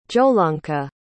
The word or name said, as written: jolanka